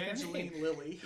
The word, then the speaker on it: Evangeline Lilly: I kind of can't stand Evangeline Jilly. I just really butchered Evangeline Lilly.